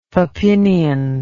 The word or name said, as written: papinian